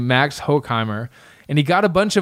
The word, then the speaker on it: Max Horkheimer: So, there was this dude named Max Horkheimer. Am I pronouncing that right? How's my German? – Say it without laughing. – Okay. Max Horkheimer. – There we go.